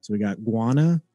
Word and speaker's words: Guyana: People's Temple Commune in Guyana.